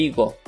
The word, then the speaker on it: higo: Эль иго. Эль иго. Иго. Пишется через H, H, E. G, O, но H не произносится, Иго.